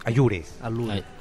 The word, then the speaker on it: Allure: Allure. – A-L-L-U-R-E.